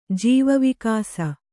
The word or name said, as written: ಜೀವವಿಕಾಸ